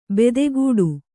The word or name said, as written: ಬೆದೆಗೂಡು